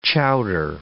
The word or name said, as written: chowder